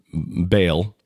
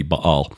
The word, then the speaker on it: Baal: And that the link between Baal, and I know that's not the proper pronunciation, but that's what most of us use, so I'll use that just for familiarity's sake. It should be Baal.